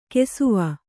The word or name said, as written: ಕುಸುವು